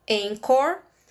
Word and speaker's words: Anchor: Se escreve A-N-C-H-O-R, Encore.